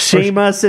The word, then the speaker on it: SEAMUS: Shame us. It's spelled like Seamus Haney, the poet. – S-E-A-M-U-S.